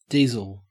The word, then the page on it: diesel: Australian English